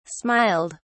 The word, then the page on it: smiled: British English